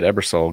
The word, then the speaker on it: Ebersole: Ebersole.